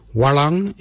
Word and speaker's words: walyan: Walang is blue. Walang is spelled W-A-L-Y-A-N, walang.